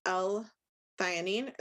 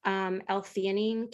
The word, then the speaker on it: L-theanine: We started with 0.5 milligram of melatonin 2 months ago and are wondering about L-theanine, is how I think you pronounce that, and 5-HTP. – I'm pretty sure it's theanine, but yeah.